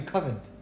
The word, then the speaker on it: Covent: Wow, you guys were saying COVID. It's actually Covent.